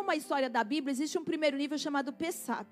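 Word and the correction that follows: Peshat: Pechat. E essa, mas não é Peixato de Páscoa não, tá? Deixa eu ver se eu estou falando certo. É Pechat.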